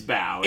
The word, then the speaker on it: Bao: That's how you pronounce it, right? It is Bao. – Yeah. – Bao, as in take a bow. – Take a bow. B-A-O. – B-A-O.